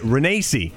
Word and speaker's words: Renacci: Renacci Renee There we go.